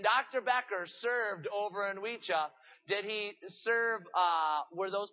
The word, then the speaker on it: Oicha: Wecha. It's spelled O-I-C-H-A. Wecha.